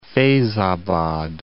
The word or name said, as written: Feyzabad